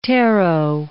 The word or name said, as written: tarot